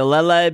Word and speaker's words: Lele: I say Lele because that's how they say it in the anime. – Yeah, because it's like ukulele.